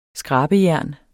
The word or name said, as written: skrabejern